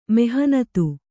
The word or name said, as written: ಮೆಹನತು